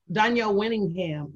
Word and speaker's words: Dawniel Winningham: Am I pronouncing your name right? – Dawniel Winningham. – Winning. Okay. Dawniel.